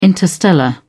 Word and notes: interstellar: British English

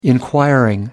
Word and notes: inquiring: American English

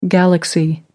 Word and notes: galaxy: American English

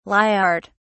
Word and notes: lyart: British English

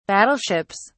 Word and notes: battleships: British English

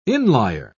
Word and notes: inlier: British English

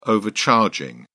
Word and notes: overcharging: British English

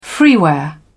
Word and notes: freeware: British English

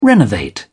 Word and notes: renovate: British English

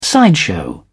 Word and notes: sideshow: British English

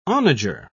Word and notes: onager: British English

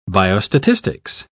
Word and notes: biostatistics: British English

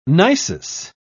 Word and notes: nisus: British English

